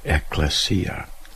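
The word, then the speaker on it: ekklesia (ἐκκλησία): So I'm going to pronounce them in Greek and listen to the first syllable of each. Eklektos, eklegomai, eklege, ekklesia.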